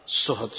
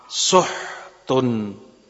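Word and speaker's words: Suhud (سحت): Suhud dibaca dengan kiroah dhammahnya sin dan ha. Suhud. Suhud. Suhud. Dibaca pula dengan sukunnya ha. Suhtun. Suhtun. Suhutun. Ada juga yang membacanya dari riwayat Nafi dengan fathahnya sin dan sukunnya ha, sahtun.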